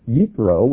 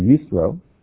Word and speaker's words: Yitro: It's Yitro, which is the Hebrew pronunciation, or Yitro. In Exodus 20, 15 and 16, God speaks to them from the mountain, and listen to what their response is.